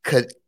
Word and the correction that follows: Ketanji: Ketanji Ketanji Ketanji Brown Jackson. Ketanji. Ketanji.